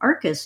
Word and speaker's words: Arkes: And Arkus is spelled Arkes.